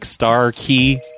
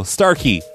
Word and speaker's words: Starkey: I was saying it like Starkey on the telephone. He says I need to pronounce it like Malarkey. So Starkey, I've got it right now.